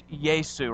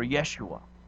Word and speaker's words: Yeshua: And the literal pronunciation of his name is Yesu or Yeshua. And that's the Hebraic pronunciation.